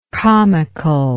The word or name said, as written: comicalness